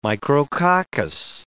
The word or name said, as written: micrococcus